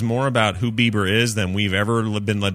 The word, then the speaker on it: Bieber: Is it Bieber or Bieber? How are we doing that one? Bieber? – It's pronounced Bieber.